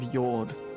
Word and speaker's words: Hjord: May they rest in the fields of Yord. Spelt with a J, so Hjord. Hjord? – Hjord.